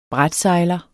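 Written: brætsejler

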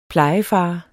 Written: plejefar

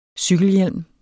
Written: cykelhjelm

